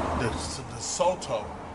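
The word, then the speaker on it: DeSoto: I'm here with a 56, the Soto, is how you say it.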